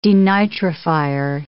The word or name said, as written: denitrifier